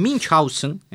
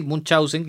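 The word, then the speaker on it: Münchhausen: Bueno, Carl Friedrich Hieronymus Baron von Munchausen, pronunciándolo así para disociarlo de esta forma de escribirla más fácil, Munchausen, que incluso podemos decirla en castellano, ¿no?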